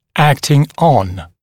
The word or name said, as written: acting on